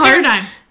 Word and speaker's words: paradigm: It's pronounced how it's spelled. – You're right. – Yeah. – Paradigm. – Like, why is there a G? – Right. – This is making it, it's a silent G.